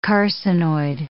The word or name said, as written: carcinoid